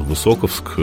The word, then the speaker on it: Высоковск: А мы в Высоковске. Я, кстати, узнал, что ударение Высоковск правильное, не Высоковск. Я почему-то раньше говорил Высоковск, то правильно Высоковск.